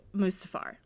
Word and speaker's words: Mustafar: to Mustafar, which is maybe how you say it. – Yeah, that is how you say it. Yes.